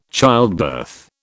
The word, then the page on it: childbirth: British English